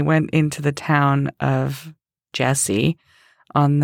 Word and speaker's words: Jesi: they announced it Jesi, and then this sort of light bulb went off in my head. I was like, oh, I've been pronouncing this wrong the entire time. So now I try and do it right. I try and call it Jesi.